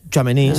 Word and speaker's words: Tchouaméni: Tchouaméni, sí.